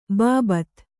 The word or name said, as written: ಬಾಬತ್